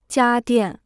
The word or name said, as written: 家电